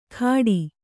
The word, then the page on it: ಖಾಡಿ: kannada